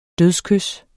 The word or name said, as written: dødskys